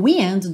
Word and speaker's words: wind: Mas que verbo é esse to wind, que se escreve igual ao wind do vento, mas tem uma pronúncia diferente, aberta, to wind.